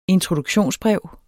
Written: introduktionsbrev

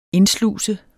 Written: indsluse

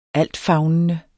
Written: altfavnende

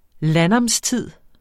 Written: landnamstid

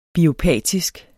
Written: biopatisk

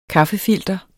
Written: KAFFEFILTER

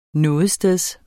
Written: nogetsteds

